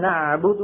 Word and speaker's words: نعبد: لأل وللميم من حم الحم نع نفس الطول نع، فلا بد للقارئ عند النطق بالعين من نعبد أن يمط صوته قليلا لأن العين حرف بين الرخو والشديد. يعني ما يقول إياك نعبد نعبد هكذا ينطقها بسرعة، إياك نعبد نع، لا بد من هذه المطة.